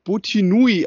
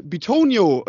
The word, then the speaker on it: Bitonio: ich glaube Butinui ausgesprochen. Mhm. Äh, oder nee, Bot Botanui hat er ihn ausgesprochen, genau, und dann sagte er, äh, äh, no correction, äh, äh, äh, äh Batoni, äh, Bitonio. Und